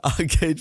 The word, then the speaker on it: Arcade: Er hat immer Arcade falsch ausgesprochen. Nee, du hast Arcade einfach gesagt, oder? Okay. Oder was? Äh, wir hatten in irgendeiner Folge, wo du Arcard. – das nicht aussprechen konntest.